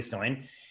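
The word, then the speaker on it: Stoain: And my friend, Julie Stoin, I always say her name weird because it's S-T-O-A-I-N, but it's Julie Stoin.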